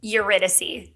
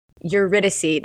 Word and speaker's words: Eurydice: Eurydice. – Eurydice.